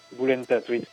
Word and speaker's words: Volentat: Volentat. Non, le... Si tu veux, le V se prononce comme un B.